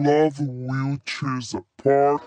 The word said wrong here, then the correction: Love Will Tear Us Apart: Errou! – Sua pronúncia está nada a ver. Corrija isso. Love Will Tear Us Apart.